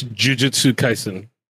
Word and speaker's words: Jujutsu Kaisen: Am I saying it right? Or Jujutsu? – It's Jujutsu Kaisen.